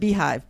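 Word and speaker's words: beehiiv: It's called Beehive. Beehiv. So it's spelled with, instead of HIVE, it's HIIV.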